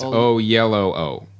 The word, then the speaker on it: Oyelowo: We got a bunch of tweets telling Oyelowo. Us that it's Oyelowo. – Oyelowo.